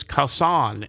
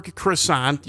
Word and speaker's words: croissant: In English, that's how we pronounce it, and in French is croissant. And so, you know, I can see where croissant, you know, could be difficult to pronounce, but that one I've never really had trouble with.